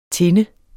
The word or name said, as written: tinde